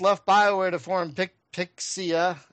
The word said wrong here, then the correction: Praxia: I'm saying that right. – Praxia. Praxia. – God damn it. I thought I said it.